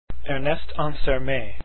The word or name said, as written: Ansermet